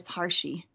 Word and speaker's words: Harshi: My name is Harshi. – Harshi. How do you spell that? – H-A-R-S-H-I.